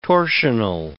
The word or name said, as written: torsional